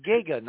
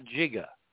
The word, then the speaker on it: giga: It's Giga, not Jigga.